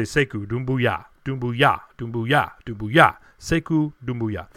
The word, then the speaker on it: Sekou Doumbouya: I was putting the emphasis on the wrong syllable, unfortunately, and I was going early in the season and throughout most of the year, Sekou Doumbouya. And I was close. I had all the syllables pronounced correctly, but the emphasis, like I said, wrong syllable. So Sekou Doumbouya. Sekou Doumbouya. Let's get this right from now on.